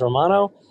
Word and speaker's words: Romano: My last name is Romano, spelled the same way, R-O-M-A-N-O.